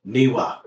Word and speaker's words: Newe: I want to say N-E-W-E. – Niwa. – Niwa. Thank you. Niwa. I knew I was going to say that. It's N-E-W-E.